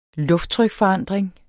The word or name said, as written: lufttrykforandring